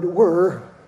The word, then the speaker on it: were: This Father's Day, June 20, 2021, the new documentary film, The Streets Where My Father, is being released. And please understand, I pronounce the word were, not where. Where as a question. Where? No. The streets were my father. W-E-R-E.